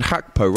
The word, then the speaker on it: Gakpo: Look, seems to go by the Dutch pronunciation Hakpo rather than Gakpo.